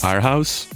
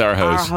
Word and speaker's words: Aarhus: How do you pronounce that? It's double A-R-H-U-S. – Aarhus. It is Aarhus.